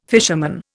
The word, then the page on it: fisherman: Indian